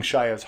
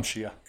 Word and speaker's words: Shia: It didn't strike me as anything except for that, except for them, you know, I think that I was seeing Shia's heart that he loves this guy and he's really proud of him and wanting to help him.